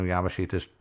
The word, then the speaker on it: Yamashita's: But as for where he got these, well, this is the Philippines, you know, Yamashita's gold and all that stuff.